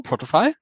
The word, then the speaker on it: Protofy: Er war früher professioneller Radfahrer und deutscher Meister und er hat sich mit seiner neuen Agentur heißt es, glaube ich, Protofy, habe ich es richtig ausgesprochen? Protofy, ja. Er nickt.